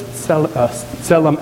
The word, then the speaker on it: Tselem: So that's Tselem Elohim. The T isn't usually there, but it's pronounced, but not in the transliteration.